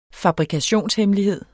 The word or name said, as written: FABRIKATIONSHEMMELIGHED